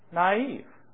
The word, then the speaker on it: naive: We come to another word, naive. And all of a sudden, it's pronounced in two syllables. But what's the difference? Those two little dots over the I.